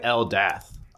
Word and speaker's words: Eldath: Of Eldick. – Of Eldick. – Your altar. – Of Eldick. That's good. That's really good. – No, of Eldath. – Oh, right. Yep. – That's how it's pronounced.